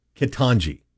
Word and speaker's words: Ketanji: Ketanji Brown Jackson? – Close enough. – How do you say it? Ketanji. Yeah, okay, I'm good there.